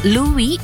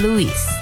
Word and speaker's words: Louis: Ada satu pertanyaan yang sering muncul sebenarnya, apakah nama Louis itu diucapkan Louis atau Louis? Berdasarkan rekaman di rumahnya, Armstrong sendiri lebih sering mengucapkannya sebagai Louis. Tapi teman, penggemar, dan banyak penyiar juga memanggilnya Louis.